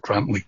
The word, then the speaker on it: Grantully: It's spelled Grantully, but apparently it's pronounced Grantley.